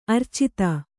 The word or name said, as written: ಅರ್ಚಿತ